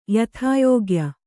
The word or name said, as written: ಯಥಾಯೋಗ್ಯ